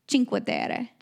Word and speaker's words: Cinque Terre: In Italy they say chink, right? Cinque Terre. – Chink, yeah. – Yeah, so that's what we're going with. But we got to Cinque Terre.